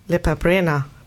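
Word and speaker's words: Lepa Brena: Nämlich Lipa Brena. Habe ich es richtig ausgesprochen eigentlich? – Ja.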